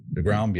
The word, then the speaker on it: deGrom: DeGrom, yeah.